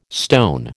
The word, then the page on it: stone: American English